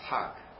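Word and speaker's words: Hag (حج): You know how to say Hajj in Egyptian Arabic is Hag. Yeah, because they pronounce the Jim like G.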